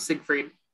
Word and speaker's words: Siegfried: Is that how you pronounce it? Yep, Siegfried.